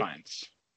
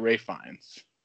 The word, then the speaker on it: Ralph Fiennes: He pronounces it Ralph Fiennes. – Okay. – I know it doesn't look like that, but that's that's what he, that's how he's been called in on, yes, all the television I've seen, and he said that's correct.